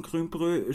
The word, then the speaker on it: Grünbrö: Wie spricht man Grünbrö? Spreche ich das richtig aus und was bedeutet das genau? – Genau. Ist richtig ausgesprochen.